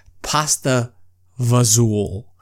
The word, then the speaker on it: fagioli: It's spelled F-A-G-I-O-L-I. So some people say pasta fagioli. Some people say pasta fagioli. Like, there's just so many ways to actually say this.